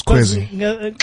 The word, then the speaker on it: Kwezi: So how do you come up with is it Kwezi? Am I saying it right? – That's right. That's Kwezi.